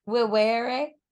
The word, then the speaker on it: vivere: Vivere. – I would slur the shit out of that. – It's V-I-V-E-R-E, but V's in Latin are pronounced with W's.